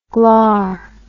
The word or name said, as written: glar